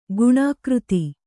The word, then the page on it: ಗುಣಾಕೃತಿ: guṇākřti